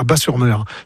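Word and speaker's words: Batz-sur-Mer: Et surtout, la crêperie s'appelle le Dervin à Bas-sur-Mer, qui font les meilleures crêpes de la domaine. – Oui, Bat, on prononce Bas, le T ne se prononce pas, Bas-sur-Mer.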